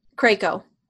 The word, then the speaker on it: Krakow: Krakow. – Krakow? Oh. – Yeah, like Draco. – I've been saying it wrong.